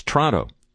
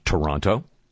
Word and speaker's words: Toronto: You go to Milwaukee, and it's Milwaukee. It's not Milwaukee. And similarly, here, it's Toronto. It's not Toronto.